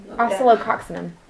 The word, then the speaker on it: Oscillococcinum: I don't know how you pronounce it. – Oscillococcinum. Oscillococcinum. – That's it.